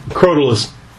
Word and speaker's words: Crotalus: How do you pronounce that properly? – Crotalus. – Okay. – Or Crotalus.